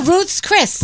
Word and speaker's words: Ruth's Chris: Oh, Ruth's Chris. – Ruth's. – Yeah, it's Ruth's.